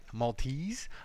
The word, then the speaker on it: Maltese: So if you're from Malta, DM me with a legit Maltian address. Is that how you say it? Maltese address.